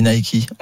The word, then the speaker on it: Nike: Parce qu'on dit Nike, on dit pas Nike. – Oui, parce que ça vient du grec. – Non, parce que ça se prononce en anglais. Comme tu dis Adidas, tu dis pas Adidas.